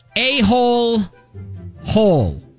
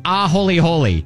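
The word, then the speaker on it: aholehole: Now, I want to say this word, a-hole, hole. But it's pronounced a-holy-holy. Holy. – Exactly. – But it reads like a-hole-hole.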